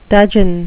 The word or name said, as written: dudgeon